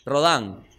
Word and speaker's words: Rodin: Ah, Rodin es por la escultura, claro. Listo. ¿Cómo se pronuncia? Rodán. Ah, ahí decía Rodin, me vas a disculpar.